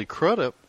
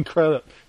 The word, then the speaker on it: Crudup: But if you put in Billy Crudup. – Billy Crudup, yeah.